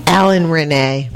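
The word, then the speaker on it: Alain Resnais: Alan Renee.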